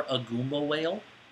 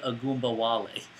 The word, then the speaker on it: Ogunbowale: So, I know that you can pronounce his name because it's Dare Ogunbowale. So it's Dare Ogunbowale. It's easy, guys.